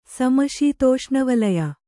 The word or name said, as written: ಸಮಶೀತೋಷ್ಣವಲಯ